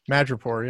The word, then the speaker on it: Madripoor: Is that how you say it? – Madripoor? Okay. Madripoor, yeah.